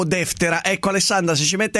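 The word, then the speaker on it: Deftera (Δευτέρα): Alessandra, l'accento sulla E prima, deftera, o sulla E seconda, deftera, o sulla A, deftera, alla francese.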